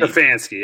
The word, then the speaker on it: Stefanski: Stefanski, yeah.